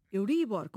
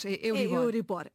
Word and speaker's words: Euribor: Euribor, como palavra grave. No entanto, quando o português a importou, e a tornou, e é um acrónimo, continua a ser um acrónimo, ela passa a seguir as regras de pronúncia da língua de acolhimento. E, portanto, o português tornou a palavra aguda.